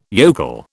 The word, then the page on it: yokel: British English